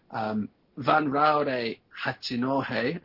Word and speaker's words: Vanraure Hachinohe: Vanraure Hachinohe, I hope I pronounced that right. – Very, very well done.